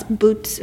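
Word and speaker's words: Buttes: No, it's Boots. It's B-U-T-T-E-S.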